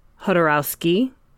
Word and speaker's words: Jodorowsky: Which is The Inkle by, it's pronounced Hodorowski and Mobius. Mm-hmm. But Hodorowski is spelled Jodorowsky.